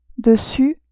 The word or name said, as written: dessus